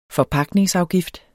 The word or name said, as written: FORPAGTNINGSAFGIFT